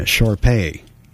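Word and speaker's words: Shar-Pei: All right, we're looking for Moxie Lost, a short-haired red Sharpie mix. Yeah, Sharpay. Excuse my pronunciation there.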